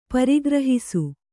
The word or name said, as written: ಪರಿಗ್ರಹಿಸು